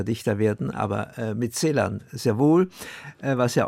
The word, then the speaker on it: Celan: Leute gibt es, die sprechen in Celan oder Celan aus. Ich sage immer, er hat sich immer gemeldet am Telefon mit hier ist Paul Celan. – Also die Betonung auf, auf der, ersten, der ersten Silbe.